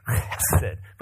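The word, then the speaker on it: hesed (חֶסֶד): It's not well translated, but it is the Hebrew word hesed. Okay, can you guys say that? Hesed. It's actually hesed, but it's difficult to say. H-E-S-E-D is kind of how you spell it. Some people spell it C-H-E-S-E-D.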